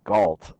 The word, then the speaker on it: Galt: And it's like my last name's Galt, like G-A-L-T, like who is John Galt, like kind of situation.